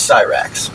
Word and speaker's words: Cyrax: I want to give it to you because it sounds like you're saying it right. Which is Cyrax. But I know you're putting a B in there.